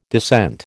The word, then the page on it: descent: American English